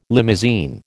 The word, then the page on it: limousine: American English